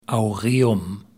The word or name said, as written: Aureum